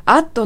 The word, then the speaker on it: add: add の D の音が発音されていないからですね。